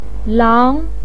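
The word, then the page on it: lang: tone 2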